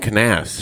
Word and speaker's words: Knauss: Yeah, so it's not a silent K. I always thought it was a silent K. – But who's Jim Knauss? – Jim Knauss.